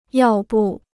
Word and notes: 要不: yào bù

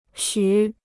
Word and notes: 许: xǔ

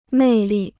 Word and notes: 魅力: mèi lì

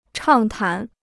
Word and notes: 畅谈: chàng tán

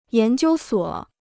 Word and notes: 研究所: yán jiū suǒ

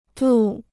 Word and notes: 渡: dù

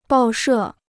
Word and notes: 报社: bào shè

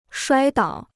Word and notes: 摔倒: shuāi dǎo

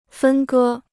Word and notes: 分割: fēn gē

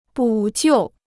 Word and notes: 补救: bǔ jiù

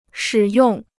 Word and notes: 使用: shǐ yòng

